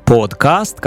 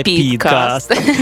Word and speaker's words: подкаст: Подкаст. Кажуть, правильно говорити подкаст, а не підкаст. – Тому що це від iPod, а не від iPad.